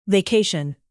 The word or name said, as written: vacation